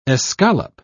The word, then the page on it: escalope: British English